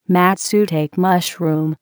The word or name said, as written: matsutake mushroom